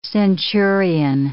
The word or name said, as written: centurion